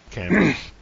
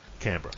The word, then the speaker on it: Canberra: We don't really have a Canberra, and I think I pronounced that wrong. – Canberra. You don't lean into the B in the middle. – B. – Canberra.